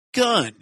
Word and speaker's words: Gunn: And of course, you know, and fun rhymes with Gunn.